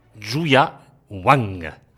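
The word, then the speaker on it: Yuja Wang: Au nom impossible de Zhu Ya Wang, et je prononce comme l'a dit mon ami le compositeur, c'est toi Jean-Jacques Dituxi, Zhu Ya Wang, une pianiste absolument extraordinaire.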